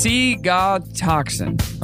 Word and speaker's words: ciguatoxin: There is a poison called ciguatoxin, pronounced C-God-toxin.